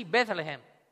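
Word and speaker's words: Bethlehem (בית לחם): Y coincidentemente, en hebreo también se pronuncia así, Bethlehem.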